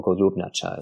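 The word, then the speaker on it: Golubnjača: They found it was a place known only to local people called the Pigeon Cave, or Golubnacha is how it's pronounced in the local language.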